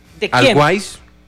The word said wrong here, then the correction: Always Ready: ¿De ¿Sí? quién? ¿Se dice así? – Always Ready. – ¿Cómo? – Always Ready. – Always Ready. Sí.